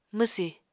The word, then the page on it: mwsì: Apatani